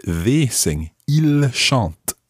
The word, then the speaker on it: ils: So we've got il in the plural, I L S, il. – Il. – But the S is silent unless it's followed by a vowel.